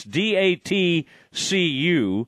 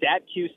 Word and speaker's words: DATCU: Yeah, how do we say this? – I believe it is called DATCU Stadium okay, and that's some kind of financial type place or whatever. But okay, DATCU is what we're going to say.